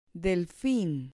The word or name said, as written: delfin